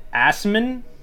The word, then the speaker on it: Assmann: Um Jan I'm pretty sure it's pronounced Assman but it's spelled Assman.